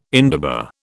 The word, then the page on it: indaba: British English